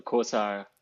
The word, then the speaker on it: Kausa: And so the first question would be like, how do you pronounce Kausa in an international background? Because I would know how I would pronounce it in German, but how do you do this in the English speaking world? – I think you were spot on.